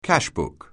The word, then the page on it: cashbook: anglais